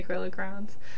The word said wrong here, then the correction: crayons: Oh, I apologize. That was a mispronunciation.